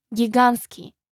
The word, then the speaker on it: гигантский: Например, прилагательное «гигантский» – «giant» имеет непроизносимую согласную «t» в середине.